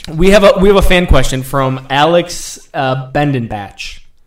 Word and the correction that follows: Biedenbach: Alex Biedenbach. Yeah, Alex Biedenbach. Oh, Biedenbach, dude. Oh, okay. Biedenbach. God, you butchered that name, bro.